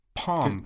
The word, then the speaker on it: Pom: Did you say Palm? – Palm. – Can you spell it? – P-O-M.